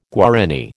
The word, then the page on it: Guarani: American English